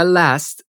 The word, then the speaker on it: last: La palabra last quiere decir último, pero en este contexto quiere decir pasado. No olvides pronunciar la T al final. Last.